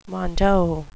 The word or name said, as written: mondo